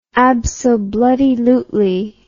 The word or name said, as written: absobloodylutely